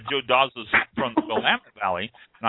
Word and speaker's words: Willamette: Yeah, no, that's the way we pronounce it in this area. – Ah, okay. – It's like you say tomato, I say tomato, I guess. – Yeah. – But I just struck me as strange because I've always heard Willamette, so the accent on the end.